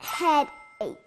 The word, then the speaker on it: headache: Headache. – 네, 아주 잘했어요. Headache. 여기는 끝에 있는 H가 silent H예요.